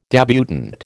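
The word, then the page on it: débutante: American English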